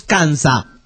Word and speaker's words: Kansa: Las palabras Topeka y la palabra Kansas vienen de un dialecto que hablan los indios Kansa. No con ese final, Kansa.